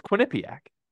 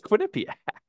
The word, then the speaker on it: Quinnipiac: Quinnipiac? – Quinnipiac. – You're just putting a lot of emphasis on the N. Quinnipiac. – Quinnipiac. – Quinnipiac. – It's Quinnipiac.